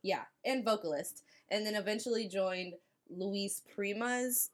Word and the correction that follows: Louis Prima: uh, Louis band. Prima? Louis Prima. Fuck, I knew I was gonna say it wrong. Louis Prima's band, who and he was known as the King of Swing.